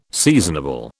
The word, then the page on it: seasonable: British English